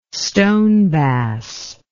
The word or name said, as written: stonebass